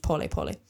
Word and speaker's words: pole pole: It is best demonstrated with a well-used phrase called pole pole, spelled like pole pole in English, but pronounced pole pole.